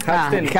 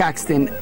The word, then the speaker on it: Haksten: Apellido preguntan también, porque Haxten. Haxten, H-A-K-S-T-E-N.